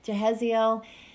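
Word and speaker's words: Jahaziel: So it made me think of what you and I were talking about earlier when we were walking, and Jehaziel, how do you say his name? – Jehaziel.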